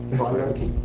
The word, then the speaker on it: Galerkin: Galerkin. – How is it pronounced? – Galerkin. Yeah? – Yeah. You even German people like to pronounce the right way.